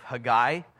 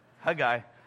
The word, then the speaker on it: Haggai: Hey, guy, what's up? Haggai. Haggai is how you pronounce it. Simply, the only reason I know that is because I have a Jewish friend whose name is Haggai, and that's how he pronounces his name.